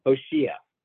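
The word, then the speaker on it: Hoshia (הושיעה): But that's in itself odd, because every other place in Tanakh, those words, Hoshia and Hatslicha, are always milal. The stress is always on that middle syllable in the hearing. Like here's another Tehillim, Hoshia, with a stress on the shin over here.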